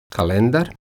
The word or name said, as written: kalendar